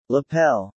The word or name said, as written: lapel